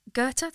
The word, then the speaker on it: Goethe: It's she's talking about Goethe, that's how you say it, isn't it? – Yeah.